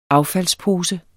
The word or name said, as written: affaldspose